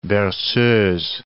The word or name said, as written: berceuses